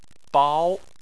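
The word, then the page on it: bao: tone 2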